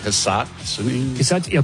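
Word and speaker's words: Hisatsune: So I have Francesco Molinari and Ryo Hisatsuni. Probably mispronouncing that last name. – It's like Hisatsuni.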